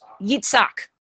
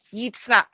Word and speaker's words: Yitzhak: How do you say it? It's Yitzhak. Yes. Yitzhak.